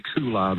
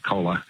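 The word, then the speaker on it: Colah: A lot of people say Kula, but Mount Kola, yep. – I like it. Good soft drink kind of sounding name. What's the highlight of Mount Kola?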